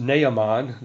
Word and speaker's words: Naaman: Well, we say Naaman, but I think it'd be better pronounced Naaman, the Syrian, a Gentile leper.